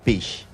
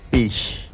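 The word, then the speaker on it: pix: que en lengua yocotán se pronuncia pish. – Sí, no te preocupes, aquí lo vamos a repetir nuevamente. Pish, así como lo escuchas, vamos a repetirlo nuevamente. Pish. Excelente. ¿Cómo se escribe? Pues vamos a anotar. Primero, letra P. – Letra P. – La letra I.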